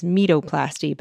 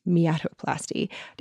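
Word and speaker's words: meatoplasty: There's a penis tip procedure that is written as metoplasty, but thankfully it's pronounced as miatoplasty, and tons more problems that I hope I never have.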